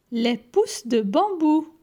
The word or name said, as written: Les pousses de bambou